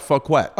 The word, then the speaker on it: Fouquet: No, it's French. I guess it's like you pronounce it like you would pronounce croquette, but Fouquet. – Okay, Fouquet.